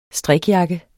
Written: Strikjakke

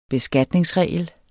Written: beskatningsregel

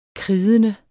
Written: kridende

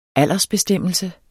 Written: aldersbestemmelse

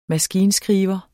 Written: maskinskriver

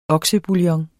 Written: oksebouillon

